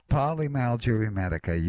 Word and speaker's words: polymyalgia rheumatica: Polymyalgic. – Okay, polymyalgia rheumatica.